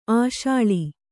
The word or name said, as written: ಆಶಾಳಿ